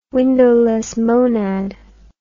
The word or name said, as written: windowless monad